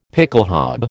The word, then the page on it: pickelhaube: American English